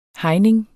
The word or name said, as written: hegning